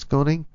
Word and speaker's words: Koenig: Yeah, Andrew Koenig or Kenning. And how do you pronounce it? K-O-E-N-I-G. You think it's Koenig? – Yeah, I think it's Koenig.